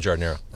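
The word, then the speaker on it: giardiniera: Now, you guys all pronounce it in the very distinctly Chicago way, Jardinera. – Jardinera, yeah. – The A doesn't exist. – Right. – People outside of Chicago don't pronounce it like that.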